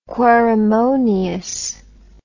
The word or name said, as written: querimonious